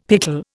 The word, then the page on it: pickle: Indian